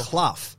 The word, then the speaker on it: Clough: And I'm like, no, it's not. It's Clough. Clough. – Like Brian Clough. It's the same. Brian Clough.